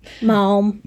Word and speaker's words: Maugham: Maugham.